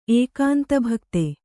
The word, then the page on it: ಏಕಾಂತಭಕ್ತೆ: ēkānta bhakte